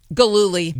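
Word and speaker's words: Gillooly: Galooly.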